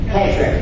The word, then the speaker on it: Kidron: Am I saying that right? Kedron? Kedron? K-I-D-R-O-N. Kedron. Okay.